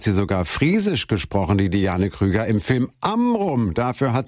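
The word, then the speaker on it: Diane Kruger: Also hallo liebe Hörerinnen und Hörer, hier ist Diane Krüger.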